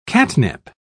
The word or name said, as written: catnip